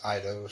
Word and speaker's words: Eidos: Anyway, so yeah, 2005, they acquired Arcade Corporation Taito, and in 2009, Square Enix acquired British game publisher Eidos, I think it's Eidos or Eidos, how do you spell it? – E-I-D-O-S. – I would say that's Eidos. – Yeah, so Eidos, which was later absorbed into Square Enix Europe.